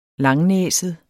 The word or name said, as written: langnæset